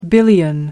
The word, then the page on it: billion: American English